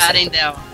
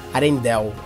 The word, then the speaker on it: Arendelle: Are, Arendelle, eu, tô, eu não sei pronunciar isso Arendelle. – Arendelle. É que não sei, em português aqui tem um E no final, Arendelle, mas eles falam Arendelle mesmo.